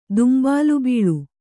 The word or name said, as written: ದುಂಬಾಲಬೀಳು